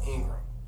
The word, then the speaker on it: Ingram: The Jeff Ingram.